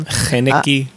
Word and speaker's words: Haneke: Haneke.